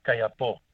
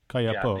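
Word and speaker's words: Kayapó: Oh, no, this is the Kayapo tribe. Is that the way you pronounce it? – Kayapo. – Kayapo. – Yeah, the accent, so to speak, is on the O at the end.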